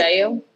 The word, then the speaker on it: Chiclayo: Chiclayo? – Yeah.